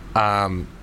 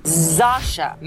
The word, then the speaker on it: Zosia: Zosia Mamet. – Okay. – That's not... spelt like that. – Zosia. Well, no, but loads of words aren't spelt the way phonetically.